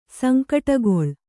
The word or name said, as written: ಸಂಕಟಗೊಳ್